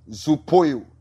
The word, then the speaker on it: zoopoieo: The word quicken in Greek is zoupoio. Zoupoio. That is Z-O-O-P-O-I-E-O.